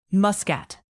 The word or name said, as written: muscat